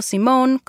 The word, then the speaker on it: Simone: Y sí, estoy pronunciando Simone con una E al final como nombre femenino.